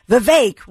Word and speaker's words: Vivek: And I remember you told me Vivek rhymes with cake, you know, or make.